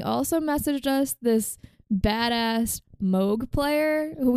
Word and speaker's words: Moog: It's not pronounced moog. What is it? Moog. No. – Yes. There's two O's. Yes. – It, that's that's the way that the guy who invented it pronounces it. Technically, it's pronounced moch, but no one can say that in English speaking countries, so it's moog.